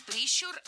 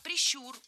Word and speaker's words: прищур: Не прищур, а прищур. Ударение в этом слове ставим на второй слог. Да там его и оставляем во всех формах. Только прищур, прищура, прищуре.